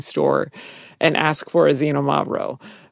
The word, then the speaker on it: Xinomavro: And so sometimes how Xinomavro is actually spelled, it's X, it's not Z, it's Xinomavro. But sometimes instead of XI, it will be XY. So, and then how you pronounce it. – Because they can't agree. – And then they can spell it however they want. – Yes, and it's pronounced with sounding like it's a Z, and it's actually an X.